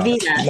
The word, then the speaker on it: Velveeta: So a Belvita. Belvita? Belvita? – The Vita, there's no L.